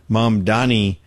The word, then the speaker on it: Mamdani: I got to throw this Mondami. I keep saying his name wrong. Is it Mamdani or Mondani? Dami. Anyway, Mamdani.